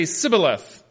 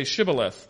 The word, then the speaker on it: Shibboleth: and they began stopping people and asking them to pronounce the word Shibboleth. And the people on the eastern side could only say Sibboleth, and the people on the western side would say Shibboleth.